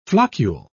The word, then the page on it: floccule: British English